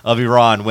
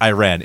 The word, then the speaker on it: Iran: So you keep calling it Iran. It is not Iran. It is Iran.